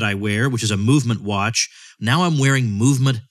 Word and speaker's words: MVMT: MVMT, pronounced movement, has hundreds of premium, affordable sunglass styles to choose from.